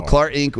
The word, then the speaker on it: Clarink: So Chadwick is the founder and owner of Clarink. I think is how you say that. Clarink. – Clar. Oh, Clar Inc. – Yes, Clar. – No, Clar Inc. with a K.